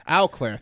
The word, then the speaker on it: Eau Claire: Eau Claire, thank you.